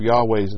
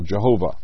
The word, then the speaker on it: Yahweh: It has become apparent through biblical studies in the 20th century that the correct pronunciation and spelling of God's name should probably be Yahweh rather than the traditional Jehovah.